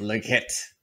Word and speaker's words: Lockett: I mean, you're not going to pronounce his name right? Okay. – Oh, get out of here. – Really? 10 points. 10 points for mispronunciation. – Yeah. – Agreed. – As a Seahawks what? – Fan. – What? It's outrageous. – Lockett. Also, he's not French.